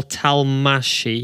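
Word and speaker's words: Talmácsi: Talsmashi, is it? I'm absolutely butchered it. There you go. Gabor Talmashi.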